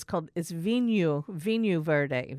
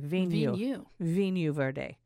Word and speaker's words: Vinho Verde: No, but so you know, in Portuguese, they pronounce things differently. I tend to want to put the Spanish pronunciation, but it's not. So I say Vinho Verde. It's Vinho Verde. Vinho Verde.